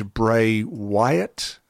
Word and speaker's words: Bray Wyatt: I was especially moved by the tribute to Bray Wyatt."